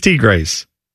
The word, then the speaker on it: Tigres: Tigers or Tigres? – It is Tigres.